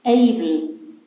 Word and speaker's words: able: Das spricht man able.